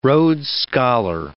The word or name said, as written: Rhodes scholar